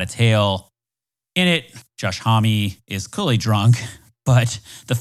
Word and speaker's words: Homme: That lead singer's name is pronounced Josh Homme. The last name looks like Home or Om, like French man.